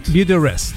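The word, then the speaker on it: Beautyrest: Beauty Rest. Antes de esa campaña, ¿lo dije bien, compadre? – Sí, muy bien.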